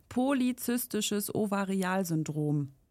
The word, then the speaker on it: polyzystisches Ovarialsyndrom: Und PCOS steht für polyzystisches Ovarialsyndrom. Habe ich das richtig ausgesprochen? Ja, ne? – Ja.